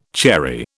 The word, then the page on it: cherry: British English